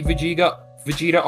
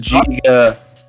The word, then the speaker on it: Vegeta: Also, mad respect to the copy Vegeta arc. – It's Vegeta. – Vegeta. – Yeah, I said it wrong.